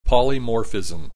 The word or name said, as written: polymorphism